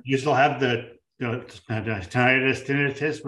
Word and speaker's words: tinnitus: Have a doctor friend pronounce it tinnitus. – So there you go. – I think that's right. – That's definitely right. – I've always said tinnitus.